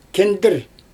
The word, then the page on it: kindIr: Xyzyl